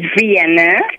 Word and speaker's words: Vienna: It's Vienna. V-I-E-N-N-A.